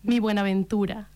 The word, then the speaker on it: Mi Buenaventura: Sur ce premier titre de cet album, Ma Buenaventura, je l'ai bien prononcé, vas-y. – Mi Buenaventura.